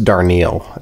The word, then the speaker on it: Darnielle: I think his name is pronounced Darnielle.